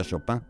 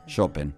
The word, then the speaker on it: Chopin: Como Chopin, que todos le decimos Chopin, pero él no se decía Chopin. Chopin. – Chopin.